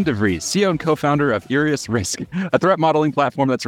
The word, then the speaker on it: IriusRisk: IriusRisk.